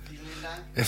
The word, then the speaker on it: Vielen Dank: Vielen Dank.